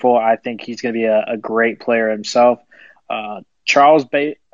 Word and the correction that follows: Bassey: I'm probably getting that name wrong because I'm the – Bassey. – Is it Bassey?